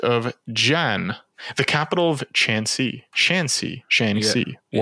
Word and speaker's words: Shanxi: I think in Mandarin, how do you pronounce the X? Is that a Qi? Or is it a? – It depends. Like this is pronounced Shanxi.